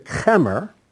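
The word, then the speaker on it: chemer (חֶמֶר): the word there for tar is the word chomer. It's spelled like this. Let's see, is there a vav in that? No, there's no vav. Ket mem resh. Chomer. That's how you would spell it in English. So chomer means tar. But if you change the pronunciation, don't change the spelling, just change pronunciation to chemer. Chemer means wine.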